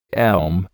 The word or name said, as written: elm